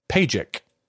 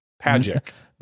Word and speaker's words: Pajic: So Ray's telling me that Zach's last name is pronounced Pajic, like magic. Pajic. – Not like Pat Sajak. – No, not like Pat Sajak, but it's magic. – Pajic. – Magic with a P, so like Mapic.